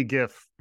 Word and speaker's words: GIF: I'll work on maybe going GIF next time. But I'm a I'm a soft G. I'm a hard G on the court, but I'm a soft G when it comes to my gifts.